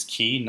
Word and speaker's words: quay: I went down to the Keys and spelled Q-U-A-Y. It is key, not quay. A lot of people seem to make that mistake. It does come from the French key, so that's where you get the pronunciation. So if you see Q-U-A-Y whilst traveling to an island or a place surrounded by water, it is key, not quay.